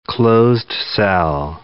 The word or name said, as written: closed-cell